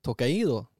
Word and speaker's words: Tokaido: Ett Tokado... Nej, Tokado. Tokaido? Tokaido! – To Tokaido tror jag det ska uttalas.